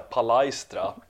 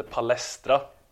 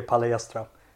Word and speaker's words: palestra: Däremot är det så här att om man ska ty tydligen är det så att om man ska uttala det på riktigt så ska man säga palaistra. Ja, men det var och ska man uttala det på svenskt så ska man säga det palestra. – Det, det är palestra.